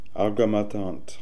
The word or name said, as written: Algamatant